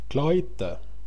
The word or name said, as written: klajte